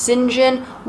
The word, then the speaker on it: St. John: That is spelled St. John. It's pronounced St. John. Why? I don't know.